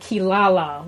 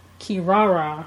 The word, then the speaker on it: Kirara: her name is spelled Kirara, but how they pronounce it in the dub English version is like Kilala with like an L, which I always thought was Kilala. I was like, no, it's Kirara.